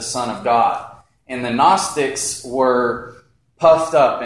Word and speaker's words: Gnostics: Starts with a G, but the G is silent. Gnostics.